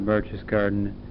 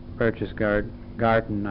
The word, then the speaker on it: Berchtesgaden: It was about 6 o'clock when we got into Berchtesgaden, I guess I should be pronouncing it instead of saying garden, should be gotten, some similarity to that.